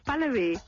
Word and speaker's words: Pahlavi: You're saying it correctly today. It's Pahlavi.